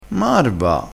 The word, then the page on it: marva: dialettale